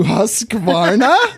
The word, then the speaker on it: Husqvarna: Hey, Misfits, I've noticed that many people in the U.S. pronounce Husqvarna with an A in the middle. Husqvarna! Since this A isn't actually in the company name, it leads me to ask, does this mean that Yamaha is pronounced Yamha? Where the actual A in the middle of Yamaha is silent. So it can be loaned to Husqvarna? For what it's worth, I'm told the manufacturer pronounces their company name Husqvarna.